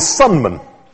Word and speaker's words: Sunman: And my surname is Sunman. That's kind of sun, big ball of hot gas. Don't know why that came into my name. And man, as opposed to a woman.